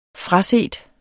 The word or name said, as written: fraset